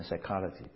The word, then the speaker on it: psychology: 当然我们英文的 P 通常不发音，所以这边 psychology 那个 P 不发音的，希腊语是要发的。